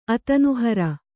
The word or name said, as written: ಅತನುಹರ